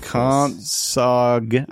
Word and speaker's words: Compsognathus: Compsog. I like to pronounce the G because here's why.